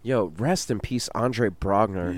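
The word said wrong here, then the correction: Braugher: Or Andre Brower, is it? – Andre Brower. – I don't know how to pronounce that, man. It's Brower? Yeah.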